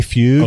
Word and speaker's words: Fueg: Can you say his name one more time? – Rene Fugue, okay. F-U-E-G. – Got it.